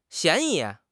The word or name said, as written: 嫌疑